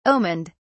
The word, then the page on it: omened: British English